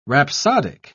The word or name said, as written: rhapsodic